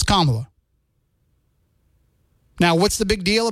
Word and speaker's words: Kamala: First of all, her name is Kamala Harris, not Kamala. And if you don't learn how to pronounce it correctly, then just call her Harris.